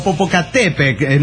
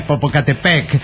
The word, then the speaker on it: Popocatépetl: Lo que nunca falla, si tiene alguna duda en la pronunciación, recuerde que el acento va siempre en la penúltima sílaba. ¿Me entiende? Póngale, muchos dicen Tenochtitlán y no es Tenochtitlán. Ah, bueno, gracias. Claro, o Popocatépetl, no es Popocatépetl, y así.